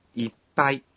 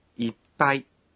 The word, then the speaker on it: いっぱい: いっぱい có chứa âm ngắt. Khi phát âm thì phải nhịn thở một nhịp.